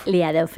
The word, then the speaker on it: Liadov: Eso es, Lo he dicho bien, ¿no? Era Elia Dov. – Liadov,